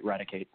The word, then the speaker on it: Raticate: And just so you know, that is how you pronounce that. You got that right, Raticate.